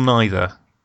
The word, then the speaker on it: neither: You are neither. – Excellent. I'm Adam. Excellent. – Is it neither or neither? – I think it just depends on how much of a pronunciation Nazi you are. – Either works.